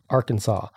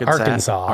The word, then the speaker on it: Arkansas: Arkansas. Why is it pronounced Arkansas? – Arkansas.